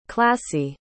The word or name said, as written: classie